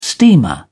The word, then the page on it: steamer: British English